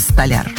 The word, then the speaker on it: столяр: Сегодня в рубрике «Говорим на русском» разберем слово «столяр». Да, вы не ослышались, именно здесь нужно ставить ударение, если мы хотим сказать о специалисте по обработке дерева. Ничего не говорите, очередное открытие. Оказывается, всю жизнь я говорил неправильно, придется переучиваться.